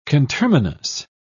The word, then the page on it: conterminous: British English